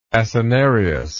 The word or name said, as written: acinarious